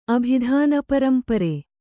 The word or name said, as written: ಅಭಿಧಾನಪರಂಪರೆ